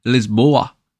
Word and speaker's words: Lisboa: It's about my recent trip to Lisbon in Portugal, or Lisboa, as the locals say.